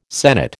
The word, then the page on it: sennit: American English